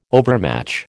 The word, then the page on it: overmatch: American English